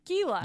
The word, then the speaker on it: Gila: Gila.